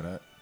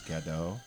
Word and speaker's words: Gadot: And a lot of people mispronounced it as Gadot. But she made it very clear. She's Israeli. She's Jewish. And she made it very clear that you pronounced the T.